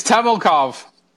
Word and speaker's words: Temelkov: It's Temelkov.